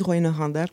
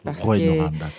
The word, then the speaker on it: Rouyn-Noranda: Par contre, j'aime dire que je viens de Rouyn-Noranda. – Rouyn-Noranda, tu le dis tellement bien.